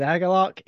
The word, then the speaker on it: Agalloch: Is it Agalloch? – It is Agalloch, yes.